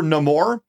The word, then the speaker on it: Namor: He's got wins over Namor, the Seaman, their version of Aquaman. Did I pronounce it wrong? – You pronounced it the French way, which was cool.